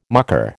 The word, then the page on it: mucker: American English